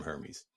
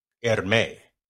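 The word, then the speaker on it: Hermès: Outside of and apparently the H is silent. Hermes. Gotta talk. Hermes. See, I roll the R's there.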